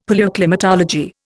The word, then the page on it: Palaeoclimatology: Indian